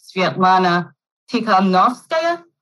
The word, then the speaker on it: Svetlana Tikhanovskaya: Basically, Lukashenko jails one political opponent only to have him replaced by the opponent's wife, Svetlana Tikhanovskaya. Did I get that pronunciation correct? – Absolutely.